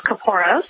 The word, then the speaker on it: Kaporos: Well, there are several pronunciations. We tend to stick to the pronunciation of Kaporos, but you might hear others refer to it as Kaporos or Kaporos. So you'll hear several incarnations depending on who you speak with, but we tend to just say Kaporos.